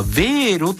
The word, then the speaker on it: Wero: ドイツ公共放送 NDR よりデジタル主権は市民にも、欧州初決済サービス、これはヴェーロと読むそうです、 WERO 広がるということで、これ教えてください。